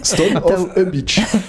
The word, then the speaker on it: Stone of a Beach: Je vous ai vu rire quand je lançais le nom, je l'ai mal prononcé. – T'as oublié un mot. – Stone of a Beach. – En plus, j'ai entraîné. – Stone of a Beach. Voilà, je me suis trompé et je m'étais déjà trompé en préparant l'émission dans le mail.